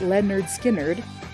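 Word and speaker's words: Lynyrd Skynyrd: It reached its peak on January 25th of 75, although the song originally appeared on Skynyrd's 1973 debut, pronounced Leonard Skynyrd, complete with the phonetic pronunciation marks.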